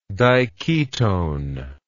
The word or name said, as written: diketone